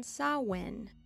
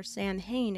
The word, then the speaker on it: Samhain: On Samhain, or Samhain as you may have heard it pronounced in America,